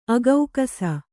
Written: ಅಗೌಕಸ

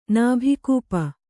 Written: ನಾಭಿಕೂಪ

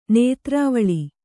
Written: ನೇತ್ರವಳಿ

